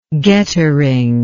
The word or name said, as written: gettering